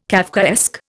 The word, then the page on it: Kafkaesque: Indian